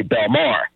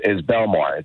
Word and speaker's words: Belmar: So, okay, so there's the one up north is called, I don't know why they say Belmar. They accent the second syllable. And this one down here is Belmar.